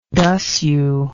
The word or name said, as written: dasyu